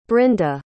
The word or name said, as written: brinda